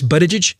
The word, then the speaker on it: Buttigieg: See, the point of Mr. And it's Buttigieg. That's how it's pronounced. I want to make sure I get it right. – Buttigieg.